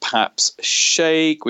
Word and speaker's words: Paps Shaikh: Okay, so more about myself, you can just find me on LinkedIn at Paps Shaikh, which is P A P S, and the surname is spelled S H A I K H.